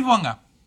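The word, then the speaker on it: Bouanga: Buanga, Buanga.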